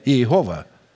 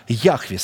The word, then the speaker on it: Яхве: И окружающие Бога серафимы, прежде чем провозгласить имя Бога Яхве Саваоф, не Иегова, потому что это неправильное произношение, а Яхве Саваоф,